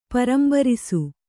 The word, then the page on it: ಪೂರಂಬರಿಸು: pūrambarisu